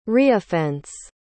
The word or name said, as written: reoffense